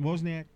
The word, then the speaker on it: Wozniak: Steve Wozniak, I always say that wrong, sort of. Wozniak. – No, that's right. – Wozniak.